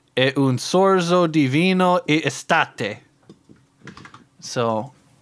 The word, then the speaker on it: estate: And, can you rhyme that for me with something? – Estate. Fuck. Fuck. – It rhymes with latte.